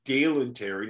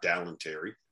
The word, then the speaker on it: Terry: It's Dalen Terry, not Dalen Terry. I got that one wrong.